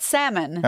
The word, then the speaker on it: salmon: Visste du att när man säger lax på engelska ska man inte uttala L-et? – Jag vet. Nej, va? – Ja. – Nej. Ja, jag vet. Salmon.